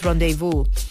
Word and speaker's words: rendezvous: I said, hey, by the way, it's pronounced rendezvous. And then, literally, so small, eh? Then why is it spelled that way, huh? Why is it pronounced rendezvous? Why is it not rendezvous when there's Z in there?